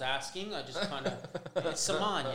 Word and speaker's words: Semaan: All right, it's spelled S-E-M-A-A-N. So, Same street. – PG, keep it PG, boys. Saman, we had the argument in the office, so we found out that it was Saman.